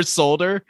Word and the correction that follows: shoulder: Shoulder is how you say that word.